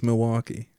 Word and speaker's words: Milwaukee: Yeah, people from Milwaukee don't pronounce the L's. And so I've always been Milwaukee? – Like, oh, that's weird. – They say Milwaukee? – Yeah, it's Milwaukee.